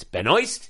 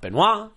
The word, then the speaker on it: Benoist: That's how you say it. It's Benoist. It's not Benoit.